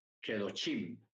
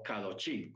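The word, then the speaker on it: Kedoshim: Baikra o Levíticos, capítulo 19, eh, la paracha Kedochim o Kadochim, como usted quiera pronunciar, Kedochim o Kadochim.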